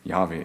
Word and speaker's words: Jahwe: kennen nur die Konsonanten aus diesem, aus diesem Wort. Äh, ähm, deshalb wissen wir gar nicht genau, wie der ausgesprochen wird, der Name. Ähm, die meisten Wissenschaftler sagen, Jahwe ist die richtige äh, Aussprache dafür.